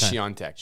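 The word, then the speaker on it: Świątek: Swiatek. Every time we say her name differently. – I think it's Swiatek. – Swiatek? – Yeah. – Let's go with Swiatek.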